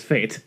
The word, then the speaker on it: F8: The is it pronounced F8 or Fate? – I vote that the internet says it's Fate.